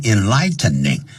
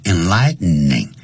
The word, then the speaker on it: enlightening: 比如说 you should learn English because it is a universal language，你这样老师这样讲话有启发性，我们就可以用 enlightening，或者是呢 inspiring，不要念成 enlightening，因为很难念，叫 enlightening 这样念。